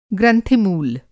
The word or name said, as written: ग्रंथि-मूल